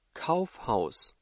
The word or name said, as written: Kaufhaus